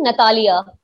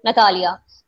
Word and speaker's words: Natalia: Yeah, you all have been pronouncing my name wrong, but it's okay. it's okay. It's actually Natalia. So it comes from Russian, Natalia. So my Instagram handle is Natalia Gul, N-A-T-A-L-I-A-G-U-L.